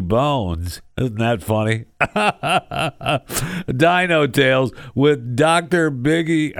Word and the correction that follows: Diggy: I want to say Biggie, but it's not Biggie, it's Diggy.